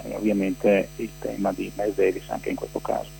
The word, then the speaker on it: Miles Davis: Miles Davis.